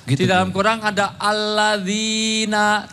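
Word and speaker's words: Alladzina (الذين): Kalau kita nggak tahu bahasa Arab, bacanya pakai transliterasi bahasa Indonesia. Alat zina. – Jauh amat dari kalau banget. Aladzina itu kalau di dalam bahasa Indonesia ditulis alat zina, malah jadi kontrasepsi.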